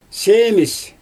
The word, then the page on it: seemIsJ: Xyzyl